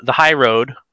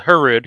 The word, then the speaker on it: HY/RD: So TRP came along and they made this little cable actuated hydraulic disc brake that they call the High Road. I refer to it as the Hurrud because that's how it's spelled, HY/RD, the Hurrud.